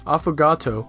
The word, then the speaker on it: affogato: 所以说，防弹咖啡的发明者还是受了西藏这个呃 buttery tea 酥油茶的启发。哎，对了，你知道我最近喝了一种咖啡，简直是大爱啊！名字叫 affogato。 – affogato，yeah，affogato 就是在一杯 espresso 里面加一勺香草冰冰激凌，然后味道很特别，又苦又甜的。 – Affogato, which is not to be confused with avocado.